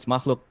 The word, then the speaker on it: Makhlvk: Nah, it's all good, man. You pronounce my name, you pronounce Makhlvk, like, perfectly. That's crazy.